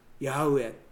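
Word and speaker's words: ヤハウェ: この 4 節には太字で主と出てるんですけれども、まあこれはヤウエというふうに発音されると今は言われています。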